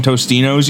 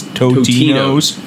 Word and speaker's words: Totino's: Like, everyone calls them Tostinos, you know, but they're actually is Totino's. That's like, but no, everyone just pronounces it Tostinos because of like the chips and stuff. – Flintstones, the Flintstones. Instead of F-L-I-N stones, it's Flintstones.